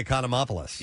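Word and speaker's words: Economopoulos: How do you say that name? – Costaki Econompolous. – You did good.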